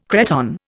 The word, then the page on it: cretonne: Indian